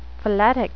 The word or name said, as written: phyletic